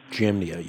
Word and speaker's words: Jamnia: I believe it's the books were called the Anti-Legionemia, the books that were in question by the council, the Jewish council, and I think it's pronounced Janima. – Jamnia, yes.